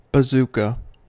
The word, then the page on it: bazooka: American English